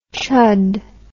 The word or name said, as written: chud